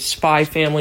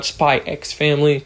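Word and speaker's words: Spy × Family: And also, for those of you that don't know, you don't pronounce the X. It's Spy Family, not Spy X Family.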